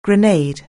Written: grenade

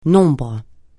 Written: nombre

